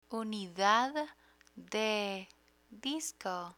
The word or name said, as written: unidad de disco